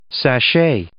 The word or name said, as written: sashay